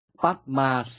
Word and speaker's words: Padma: Padma Sambhagwa. Padma hay là Padme đều là ý đó, chỉ hơi khác cách phát âm của từng địa phương, hơi sai lạc mà thôi.